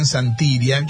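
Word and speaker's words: Santeria: Yo creo que para subestimar a los que saben poco inglés como yo. – Yo de acá estoy viendo que tiene un acento en la I y dice Santiria. – Yo escucho youtubers que hablan en inglés, entonces dicen Santiria. Pero Santería, queramos Santería. Santería.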